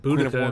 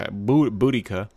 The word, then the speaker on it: Boudica: I think Boudica, she said Boudica in the trailer. – Okay, Boudica.